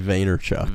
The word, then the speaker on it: Vaynerchuk: Gary Vaynerchuk. – Vaynerchuk.